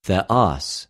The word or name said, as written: θεος